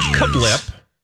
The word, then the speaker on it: CABLP: It's spelled as follows. C-A-B-L-P. – Cablop. – Holly, do you want to try? – Cablop. – Cablip. It's called Kablip, and it's short for Chris Angel's Breakfast, Lunch, no.